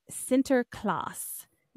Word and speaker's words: Sinterklaas: From Saint Nick, Santa Claus evolved from Nick's Dutch name Sinterklaas, and that's spelled S-I-N-T-E-R-K-L-A-A-S.